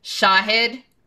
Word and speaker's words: Shahid: And his name again is Shahid Buttar, and it's pronounced like this, guys. Shahid, so the accent's on the and Buttar, the accent's on the ooh.